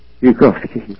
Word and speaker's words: Bukovsky: Bukovsky, is it? – Bukovsky.